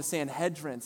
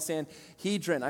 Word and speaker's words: Sanhedrin: He was a part of a high ruling council in Israel in the time of Jesus called the Sanhedrin. Sometimes people call it the Sanhedrin. I prefer the Hebrew pronunciation.